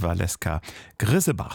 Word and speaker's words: Valeska Griesebach: Lars Meyer über Das geträumte Abenteuer von Valeska Griesebach. So wird sie wohl richtig ausgesprochen.